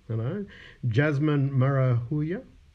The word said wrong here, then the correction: Marahuyo: Did I say that right? – Yeah, emphasize the yo. – Marahuya. – Marahuyo. – Yo. Okay. Marahuyo.